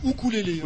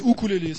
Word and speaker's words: ukulélé: On peut dire ukulélé. Normalement, c'est ukulélé. – Oui, parce que c'est la vraie UK, voilà. – Ouais, normalement, c'est ukulélé, c'est la vraie prononciation.